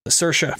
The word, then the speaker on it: Saoirse: Saoirse. That's how you pronounce her name.